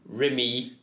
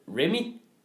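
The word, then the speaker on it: Remee: For han hedder jo R-E-M-E-E. E, -E, Remy. Ja. Alle udtaler det Remy hvilket vil være R-E-M-M-Y. Det, det er Remy.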